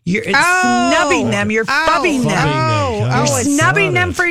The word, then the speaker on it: phubbing: We haven't really, I don't know that I've completely, am completely sold on the name fubbing. – What's it? – Fubbing. – Fubbing. – Spell it. – P-H-U-B-B-I-N-G.